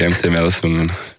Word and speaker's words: MT Melsungen: Beim MT Melsungen, oder wie spricht man es aus? – Genau, das ist richtig, MT Melsungen.